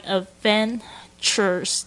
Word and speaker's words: adventures: 然后 adventures 已经不是我们讲过的第一次的这个冒险这个字哦， adventure TURES，特别注意一下它的 D 不要发太重，然后还有 V 的这个发音 Adventures 这个 ch 的这个声音 Chures。